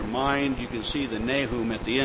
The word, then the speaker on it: Nahum: Nahum. In Hebrew, it is pronounced Nakum, and it has a particular interest once you see about the name.